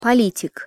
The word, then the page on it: politik: Russian